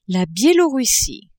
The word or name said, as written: Bielorussie